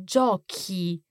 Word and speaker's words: giochi: Esatto, il plurale è giochi. – Giochi, so C-H-I at the end to keep that hard C sound.